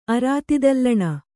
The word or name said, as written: ಅರಾತಿದಲ್ಲಣ